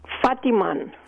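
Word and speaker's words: fattigmann: And it ranges from lutefisk to Fatigman, is that a way to pronounce it? Fatigman? – Fatiman.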